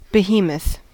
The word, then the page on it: behemoth: American English